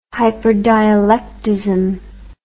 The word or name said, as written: hyperdialectism